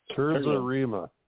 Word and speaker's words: terza rima: It's pronounced is it Terza or? – Terza Rima. – The Terza Rima, yeah.